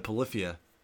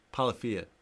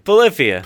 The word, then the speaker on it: Polyphia: I'm listening to a lot of Polyphia. Polyphia. – You can't even get the name right. Polyphia. You got it right the first time.